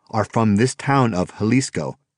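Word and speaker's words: Xalisco: Jalisco, he said, pronouncing the word. Don't confuse it with a state in Mexico pronounced the same way but spelled with a J. The state of Jalisco is one of Mexico's largest, and Guadalajara is its capital. This town, he said, spells its name with an X.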